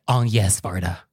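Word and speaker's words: Agnès Varda: He's like, Agnes Varda. On yes. And it's like, okay, that is the correct pronunciation.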